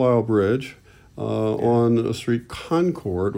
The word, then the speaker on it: Concord: And in Boston, it's said Concord is how you pronounce it.